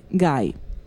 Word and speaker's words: gai: anzi provenzale, gai, si leggeva così, senza il dittongo sulla i.